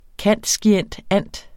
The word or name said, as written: cand.scient.anth.